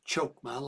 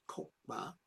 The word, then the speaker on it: Chokhmah: The firstborn, back up to Kokhmah, not Chokhmah like it sounds, but or is written, but it's actually pronounced Kokhmah, are the life, the heart, and pulse of the universe.